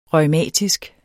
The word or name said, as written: reumatisk